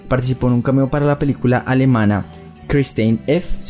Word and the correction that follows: Christiane F.: se dice así, Christiane F.